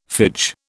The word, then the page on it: phage: British English